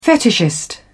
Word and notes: fetishist: British English

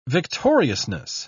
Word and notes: victoriousness: British English

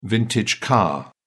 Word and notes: vintage car: British English

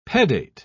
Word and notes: pedate: British English

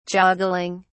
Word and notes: joggling: British English

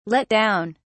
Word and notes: let down: British English

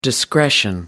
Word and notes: discretion: American English

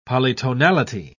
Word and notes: polytonality: British English

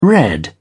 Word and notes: red: British English

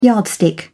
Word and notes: yardstick: English; British English

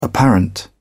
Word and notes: apparent: British English

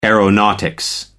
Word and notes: aeronautics: American English

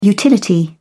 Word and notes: utility: British English

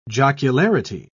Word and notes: jocularity: British English

